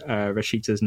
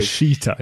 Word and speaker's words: Rashica: We'll go for Rashica. – You almost close there. It's we all fail to pronounce Rashica's name properly, including myself, because Rashita, is it? – Rashica.